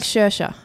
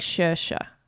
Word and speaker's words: Saoirse: It's like Saoirse. – Saoirse Ronan. – Because I remember I watched an interview with her and she's like, it's like inertia. So it's like Saoirse.